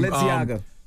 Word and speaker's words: Balenciaga: Because I can't pronounce those Balenciaga jeans I bought from Balenciaga.